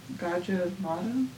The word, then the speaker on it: Gajah Mada: One thing I kind of wanted to ask you about was the Gajah Mada project. Is that how you pronounce it? – Yes.